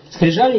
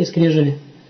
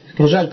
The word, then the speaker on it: скрижали: Скрижали или скрежали? Скрижали, по-моему. – Скрижали. – А? – Скрижали. – Куда ударение ставится? Скрижали, да?